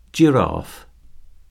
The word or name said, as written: giraffe